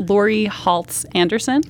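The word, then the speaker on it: Laurie Halse Anderson: So I lately have been reading Shout by, I Googled her name to see how you should correctly pronounce it, Lori Holtz Anderson.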